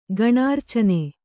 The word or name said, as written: ಗಣಾರ್ಚನೆ